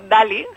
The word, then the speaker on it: Daly: El primero de ellos es Carroll Johnny Dale, es un poco, no, Daly. – Carroll John Daly.